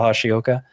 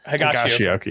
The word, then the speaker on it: Higashioka: Is that right, Kyle Higashioka? – Higashioka. – Yeah. Higashioka. Sorry. – Higashioka. I tried to correct you and said it wrong myself.